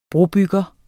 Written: brobygger

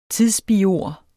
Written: tidsbiord

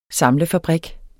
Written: samlefabrik

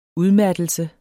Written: udmattelse

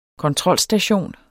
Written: kontrolstation

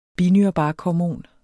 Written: binyrebarkhormon

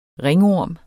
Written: ringorm

